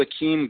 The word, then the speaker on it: Akeem: It's uh, Badru Akeem Gardner.